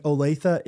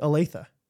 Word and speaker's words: Olathe: You use it like an E, like Olathe. – Olathe. – Yeah. – And you would like to hear more of a long O sound? – It starts with an O, so I think so.